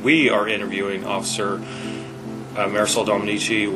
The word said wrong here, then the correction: Domenici: which is badge number... – 496. – It's actually pronounced Domenici.